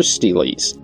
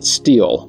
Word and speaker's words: stele: Many episodes ago, I covered other steles, and at the time I pronounced it steel because, well, that's essentially how it's written. But there is an alternative pronunciation, and that is stele. Both are acceptable, but one is far more fun to say. And that's the one I will be using.